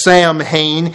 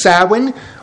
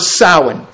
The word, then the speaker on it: Samhain: If you, as an English-speaking person, look at this word, you would declare that it is pronounced Samhain, and that is so wrong. This is generally pronounced as Samhain or Samhain.